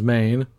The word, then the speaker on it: Mane: And then, yeah, because it is White Lion, it's spelled M-A-N-E, spells Maine.